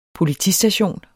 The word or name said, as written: POLITISTATION